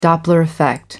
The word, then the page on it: doppler effect: American English